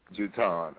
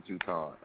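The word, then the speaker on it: Jutaun: It's J-U-T-A-U-N, Jatan. Love them. They are amazing. – T-A-U-N? – T-A-U-N, yeah. – And how you say it? – Oh, hold on. Yeah. Jatan, okay, Jatan. All right, well, we both saying that correctly.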